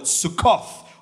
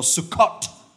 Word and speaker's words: Sukkot: Its Jewish name, which they still celebrate today, they call it Sukkoth or Sukkot or Sukkah. Lots of different ways to pronounce it, but that's how you, that's the word, Sukkot.